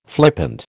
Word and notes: flippant: American English

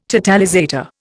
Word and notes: totalizator: Indian

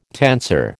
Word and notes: tensor: American English

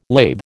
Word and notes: lathe: American English

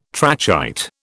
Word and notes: trachyte: British English

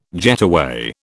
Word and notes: getaway: British English

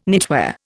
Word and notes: knitwear: Indian

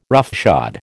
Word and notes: roughshod: American English

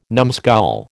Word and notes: numskull: American English

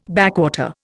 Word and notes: backwater: Indian

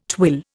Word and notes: twill: Indian

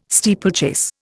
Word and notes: steeplechase: Indian

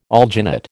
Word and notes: alginate: American English

